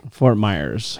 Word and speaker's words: Fort Myers: Because I knew that you mispronounced where we were living in Florida, but it was because you would say Fort Myer. – Fort Myers.